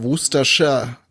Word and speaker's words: Worcestershire: Aber in der Kombination wird es dann eher Worcestershire ausgesprochen.